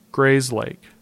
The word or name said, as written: Grayslake